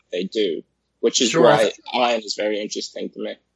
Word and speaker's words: Ayn: It is Ayn Rand, right? – Yeah. – Okay, yeah. Yeah, I was trying to look that up beforehand. So, ha. – It rhymes with mine, like Mein Kampf.